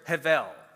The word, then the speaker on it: hevel: It's spelled with a letter B, like you see on the screen there, but it's pronounced in Hebrew with a V. So you'll often see it written out with the letter V instead of the letter B. Hevel is how it's pronounced.